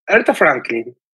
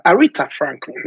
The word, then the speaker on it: Aretha Franklin: Aretha Franklin es considerada, Aretha Franklin más bien,